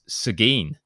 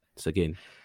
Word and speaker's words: Seguin: So we had a tweet as well from someone who picked up on our last episode when we talked about Seguin and the pecan, and apparently we got that all wrong. So this guy Eric Burkholder tweeted us saying, boys, native Texan here, it's Seguin.